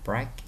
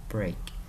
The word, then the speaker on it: break: À, thầy quên, chữ break ở đây mình đọc là âm E, giống phải âm E. Nó không phải là break nha bạn. Nó là E, nó là break.